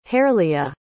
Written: hareleah

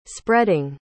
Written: spreading

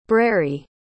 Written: breri

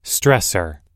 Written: stressor

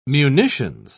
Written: munitions

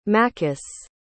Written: maccus

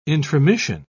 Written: intromission

